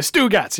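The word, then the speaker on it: Stugots: The Stugats. You got to say it like that.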